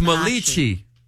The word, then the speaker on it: Malachi: But it's Malachi. – It is that is how it's spelled.